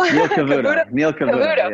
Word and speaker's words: Cavuto: Your World with Neil Cavuto. Did I say that right? I hope so. – Neil Cavuto.